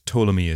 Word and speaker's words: Ptolemy: Now, before we get started, Ptolemy is spelt with a silent P at the start of the name, so if you want to Google and do some research afterwards, it's P-T-O-L-E-M-Y, and then three spelt just three.